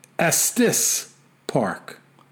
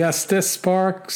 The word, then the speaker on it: Estes Park: But not to be a jerk, just for your own knowledge, Estes Park is pronounced Estes Park.